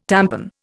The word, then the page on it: tampon: Indian